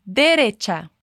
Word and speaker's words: derecha: Cuidado com a pronúncia desse derecha. Nós falamos cha, ok? Repita comigo, derecha.